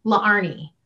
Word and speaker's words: Laarni: And really, okay, so my name is spelled L-A-A-R-N-I. The two A's right there used to give people a hiccup because really my name is pronounced Larnie.